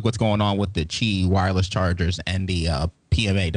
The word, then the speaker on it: Qi: And one is kind of an industry standard, the Qi, and it's QI, but they pronounce it Qi, wireless charging standard, which is what Nokia was using.